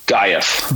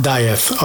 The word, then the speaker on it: Dieth: Dieth. – Dieth.